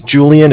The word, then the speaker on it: Julian: Okay, my name's Julian. It's J-U-L-I-A-N. – Can you spell that again? – J-U-L-I-A-N.